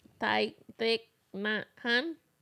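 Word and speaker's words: Thich Nhat Hanh: I was looking up the How to Eat book, but I don't know how to pronounce his name. Thai Thik Nhat Hanh. – Okay. It sounds right. Yeah, that's it.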